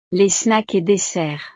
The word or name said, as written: les snacks et desserts